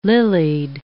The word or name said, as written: lilied